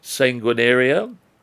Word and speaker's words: sanguinaria: S-A-N-G-U-I-N-A-R-I-A. Sanguinaria? – Sanguinaria.